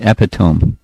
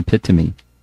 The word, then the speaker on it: epitome: You said epitome. But as far as I know, the pronunciation is epitome.